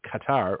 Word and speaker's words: Qatar: The Biden insurrection, my word, is claiming that the money stored in Qatar, or gutter, depending on who you talk to, an ally of Iran, pronounced Iran, will only be used for, quote, humanitarian purposes.